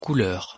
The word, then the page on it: couleur: French